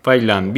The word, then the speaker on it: Vaillant: Decime si lo pronuncié bien. – Michel Bailón. – Bailant, bien. En, castellano se diría Michel Bailant, ¿no? Con doble L y con T al final. Doble L y T al final, pero los franceses no lo pronuncian, pero sí, sería Bailant o Bailant, si quieres pronunciar la L, pero viste, Bailant. Este, pero en francés es Bailant.